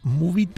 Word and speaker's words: Moovit: Que se llama Moovit, pero se escribe M-O-O-V-I-T. Uh-huh. Entonces, es una aplicación... – Con dos O's.